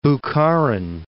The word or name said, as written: Bokharan